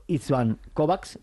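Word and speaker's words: István Kovács: Iker Zamayoa, que ya mañana hablaremos con Ivo Luriza del colegiado, el rumano Istvan Kovacs, o como se diga, ¿cómo se pronuncia? – Sí, sí, yo creo que lo has pronunciado perfectamente.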